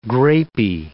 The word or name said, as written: grapey